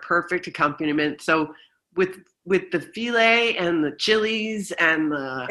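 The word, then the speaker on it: filé: It's filet, like filet gumbo? – Filet gumbo, yeah.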